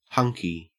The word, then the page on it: hunky: Australian English